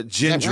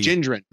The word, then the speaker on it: Gendron: Is it Gendron? – It's a Gendron. Right? Yes, soft G. – Gendron.